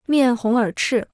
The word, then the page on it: 面红耳赤: miàn hóng ěr chì